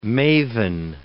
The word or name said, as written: maven